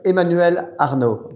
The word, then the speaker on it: Emmanuel Arnault: You want, I can give you the French way. Emmanuel Arnault.